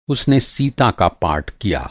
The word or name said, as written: पार्ट